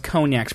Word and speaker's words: cognac: Everyone knows cognac's pronounced cognac, you know, C-O-G. – Oh, well, yeah, I don't know what it looks like. – N-A-C.